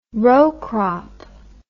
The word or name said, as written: row crop